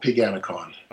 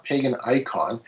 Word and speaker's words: Paganicon: And the fellow that I was talking to, he said, no. He says, it's not Pagan Icon. He says, it's Paganicon. I go, Paganicon? He goes, yes.